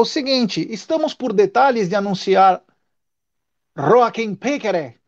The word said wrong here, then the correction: Piquerez: Piquerez, né? O Jota estava ensinando ontem como é a pronúncia.